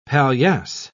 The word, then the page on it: palliasse: British English